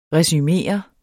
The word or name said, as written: resumere